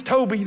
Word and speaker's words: Toby: I go, it's, my name's Toby. The fish is Goby. It's from Jesus. Toby the Goby. It rhymes.